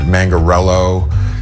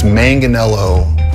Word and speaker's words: Manganiello: People that throw R's in it, Mangarello. It's always the J. Oh, Joe Manganiello. Mangagello or Mangello or Mangello.